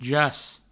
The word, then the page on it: jac: jas; Provençal